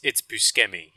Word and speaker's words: Buscemi: Buscemi, I believe isn't like the actual pronunciation Buscemi. – It's Buscemi, yeah. – Buscemi, like kind of like bruschetta.